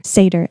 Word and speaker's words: Seder: Seder, S-E-D-E-R, something, and they would talk about how God delivered them from Egypt or whatever.